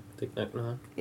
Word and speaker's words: Thich Nhat Hanh: Thich Nhat Hanh? – Yeah.